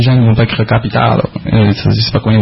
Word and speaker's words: capitada: C'est capitada, C-A-P-I-T-A-D-A. Point Escambiar. – J'insiste, on dit capitado. – Mais ça s'écrit capitada. – Ça, ce sont les subtilités de la langue occitane.